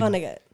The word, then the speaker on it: Vonnegut: Vonnegut.